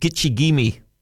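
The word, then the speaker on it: Gitche Gumee: It's from the Ojibwe. Pronounced Gitche-Gumee, meaning great sea.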